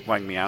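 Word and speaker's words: Wang Miao: I always just said Meow when I read his name. How did you say it, Brock? Did you look up how to say it? I said it in my head, so it was just this guy. – Wang M. – Yeah, it's Meow. Wang Meow.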